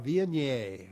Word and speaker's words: Viognier: Ah, Viognier.